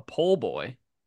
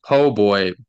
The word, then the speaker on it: po' boy: A pole boy? – No, you pronounce it po' boy, but it's spelled poor boy.